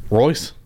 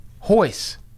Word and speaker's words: Royce: Royce? – Hoyce. – Hoyce. – Hoyce. They pronounce the R's as H's.